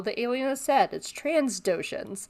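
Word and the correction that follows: Trandoshan: But it's trandotion with no S, trandotion.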